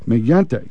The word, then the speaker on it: Mignante: Now, I think we've got the correct pronunciation of Steve's name, don't we? – Steve Mignante. – Mignante. That's right.